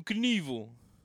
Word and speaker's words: Knievel: Also, he pronounces it Knievel. His name's Robert. – Yeah. – Robert Craig. Craig, known as Evil Knievel. – Evelyn? – Evil Knievel. That's how you say it.